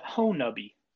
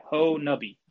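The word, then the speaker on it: Honobia: And what's interesting about Oklahoma, especially that southeastern Oklahoma region, the is it Honubia? – I've been taught that the locals pronounce it Honubby. It doesn't look like it's spelled that way, but that's how everybody says it around the area. Honubby.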